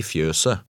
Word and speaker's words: fjøset: In number 17, the last T is silent. Read number 17. – Kua står i fjøset.